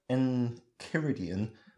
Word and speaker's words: Enchiridion: I'd say Enchiridion. That's only because I've been conditioned to pronounce C-H-I as ki, because reasons.